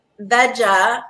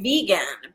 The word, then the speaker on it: Vega: Well, I guess being a green powder, I would think it would be Veja, like vegetarian, yeah, or Vega, like vegan. But it is pronounced Vega.